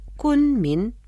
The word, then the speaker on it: 昆明: Кун произносится в первом тоне, а Мин читается во втором тоне. Повторяйте за мной. Кун Мин.